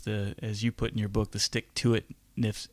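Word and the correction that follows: stick-to-itiveness: am I saying that right? Stick-to-it-iveness, right? The ability to, yeah, you got it.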